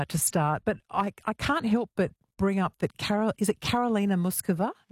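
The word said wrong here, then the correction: Muchová: Is that how we say her name? – I mean, she says Karolina Muchova.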